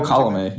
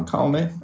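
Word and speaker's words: Colomé: But uh, you mentioned Cobb, Smyly, and even Alex Colome uh, is that how you pronounce it, by the way? – Am I oh, Colome. Yep, uh, Colome.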